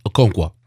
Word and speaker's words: Okonkwo: You'll become I didn't say your last name is Okonkwo, right? – Yes, Okonkwo. – Okonkwo. Okay, I'm working on it.